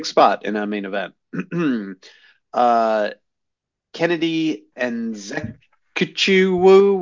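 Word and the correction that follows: Nzechukwu: what was the pronunciation? – Enzinchukwu. Yeah, you got it mostly right.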